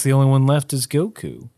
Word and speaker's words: Goku: where he couldn't pronounce Goku. Because he's like, Gaokea.